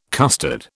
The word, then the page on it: costard: British English